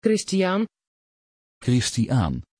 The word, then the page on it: christiaan: Dutch